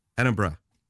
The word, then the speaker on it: Edinburgh: But am I mispronouncing? I know some people say Edinburgh. – That's wrong. Right. Oh, Edinburgh. Yep, saying it fine.